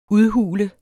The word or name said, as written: udhule